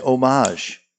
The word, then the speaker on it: homage: The H in homage is silent.